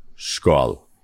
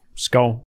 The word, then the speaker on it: Skål: Skål. – Skål. – Skål.